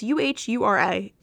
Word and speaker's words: Uhura: It's Uhura.